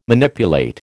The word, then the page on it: Manipulate: American English